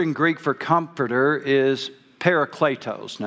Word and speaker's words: parakletos: Now, I know there's other ways to pronounce that. You don't know all that. And that's the way they taught us to say it in seminary. So that's the way I'm going to say it, parakletos.